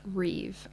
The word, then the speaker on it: Reeve: Reeve. I don't know. It's spelled R-E-E-V-E. – R-E-E-V-E. – Maybe I'm not pronouncing it correctly, but... – Revy. – That's what I'm saying with confidence. Elon Reeve Musk.